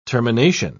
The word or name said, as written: termination